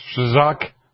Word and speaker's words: Strzok: One of the newly discovered messages, lawmakers said, appeared to indicate that Peter Strolls, or I can't pronounce that name. Peter Strzok. Strzok. What? Strzok. Yeah, that too.